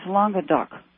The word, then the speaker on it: Languedoc: You know, the term that you used for the south of France is pronounced, the C at the end of that is pronounced, it's Languedoc.